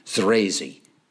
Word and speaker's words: Zrazy: Okay, the group, the two women who lead it, are called Z-R-A-Z-Y. Try pronouncing that. Anyway, it's crazy with the Z at the beginning. Thrazy.